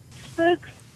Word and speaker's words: Fuchs: Fuchs dystrophy. – I'm sorry, can you repeat that? – I think it's spelled F-U-C-H, Fuchs dystrophy. – Yes, ma'am, Fuchs dystrophy. – Fuchs as in Frank. F as in Frank. – Right, yes, ma'am, F-U-C-H-S.